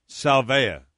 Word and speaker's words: salvia: Salvia. – Yeah, S-A-L-V-I-A. – Kind of rhymes with salvation.